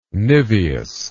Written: niveous

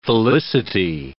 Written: felicity

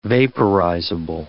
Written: vaporizable

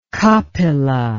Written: Kapila

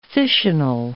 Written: fissional

